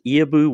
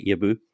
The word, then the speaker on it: Iabou: Her name is Iabou Windimere, and thank you for coming on the show today, Iabou.